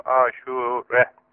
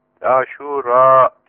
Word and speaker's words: Aşure: Bunun telaffuzu, doğru telaffuzu böyle. A harfi ve U harfi uzun. Aşure. Aşura diye, hatta bizim E diye okuduğumuz Aşura diye, efendim Araplarda uzun oluyor. Elif-i Memdude ile Aşura günü. Biz Aşure diyoruz kısaca.